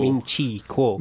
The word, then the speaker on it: Ming-Chi Kuo: It's Ming-Chu Kuo. – Ming-Chi Kuo. – Ming-Chi Kuo. All right, there we go.